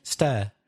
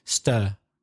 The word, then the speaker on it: stir: It's the same vowel sound as her. Aya. So her or were. W-E-R-E. Were. Her. Stir. It's that sound.